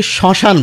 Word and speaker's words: শ্মশান: যেমন আপনি যদি শ্মশান বলেন তাহলে ওর সময় মফলা রয়েছে শুরুতে, মায়ের উচ্চারণ কিন্তু করি না, কিন্তু প্রমিত উচ্চারণে সামান্য একটি অনুনাসিক ভাব এসে যাচ্ছে চন্দ্রবিন্দুর উচ্চারণ। কিন্তু যখনই বলছেন আত্মা,